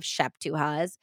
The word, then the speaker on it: szeptucha: So a szeptucha, which is spelled S-Z-E-P-T-U-C-H-A. Yeah, I read in the book it was pronounced szeptucha, so hopefully I'm right.